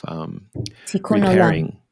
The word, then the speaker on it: tikkun olam: So I wanted to actually pick up on that last thread that you mentioned of that Judaic word of tikkun olam.